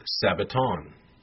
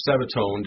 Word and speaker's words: sabbaton: The word there is the word sabbaton. Sabbaton, or sabbatone, depending on the pronunciation here, is literally Sabbath observance, Sabbatism, of a weekly Sabbath, or the Day of Atonement.